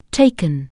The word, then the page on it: take: anglais